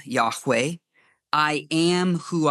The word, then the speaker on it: Yahweh: And upon the basis of that, it could be that we pronounce the name as Yahweh with an A at the end.